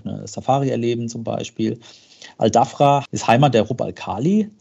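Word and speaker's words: Al Dhafra: Ähm, du hast noch Al-Dafra erwähnt, hast du richtig ausgesprochen. Al-Dafra, das ist die gesamte westliche Region Abu Dhabis mit der gesamten Küstenlinie.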